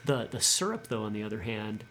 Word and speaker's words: SYRUPP: Syrup that's spelled SYRUPP, and that's based off the acronym SRPP, which, bear with me, stands for series regulated push pull.